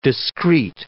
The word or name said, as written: discrete